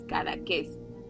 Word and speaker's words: Cadaqués: Tiene acento en la E, entonces Cadaqués.